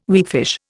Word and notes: weakfish: Indian

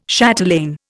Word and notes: chatelaine: Indian

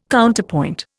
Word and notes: Counterpoint: Indian

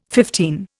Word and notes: fifteen: Indian

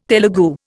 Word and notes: Telugu: Indian

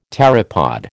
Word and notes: pteropod: American English

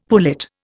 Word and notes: pullet: Indian

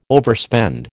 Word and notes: overspend: American English

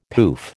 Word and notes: poof: American English

